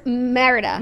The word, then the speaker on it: Merida: It's giving Merelda. What do we say her name is? – Merida. – Merida.